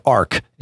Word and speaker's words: Arq: ARC is how it's pronounced, but it's ARQ.